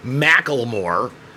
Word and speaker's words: Macklemore: It's spelled Macklemore. It's pronounced Macklemore. And he sucks. – It's so. Spelled M-A-C-K-L-E-M-O-R-E.